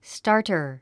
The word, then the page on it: starter: stάː r tə r; スタァタァ